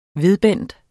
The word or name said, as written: VEDBENDEN